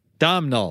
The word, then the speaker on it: Domhnall: So anyway, Domhnall.